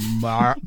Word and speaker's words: mare: In Old English, the word for these beings was mare or mire or mar. – That's the pronunciation, huh? What? – I think it's mar.